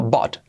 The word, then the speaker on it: bought: Fijaos que se escribe bought, pero la pronunciación es bought. I bought last year. No es una A, no es I bought, tampoco es una O.